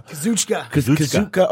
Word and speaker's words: Kazuchika: The guys like Tetsuya Naito, Kazuka. I can't pronounce his name right now. – Kazuchika Okada.